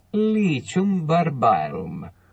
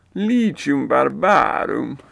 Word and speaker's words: Lycium barbarum: But so the goji berry, the scientific name is Lycium barbarum. And let me give you the Google Latin pronunciation. Here we go. Ready? – Lycium barbarum. – Lycium barbarum.